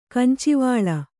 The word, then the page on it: ಕಂಚಿವಾಳ: Kannada